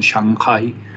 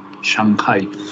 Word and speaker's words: Shanghai: no se dice Shanghai, sino Shanghai, la G pertenece a la primera sílaba y la H de la segunda suena, Shanghai.